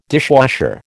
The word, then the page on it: dishwasher: American English